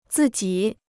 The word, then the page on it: 自己: zì jǐ